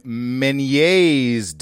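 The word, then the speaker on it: Ménière: Balance can be upset by Meniere's disease. That's French. – Uh-huh. – I would pronounce that Meniere.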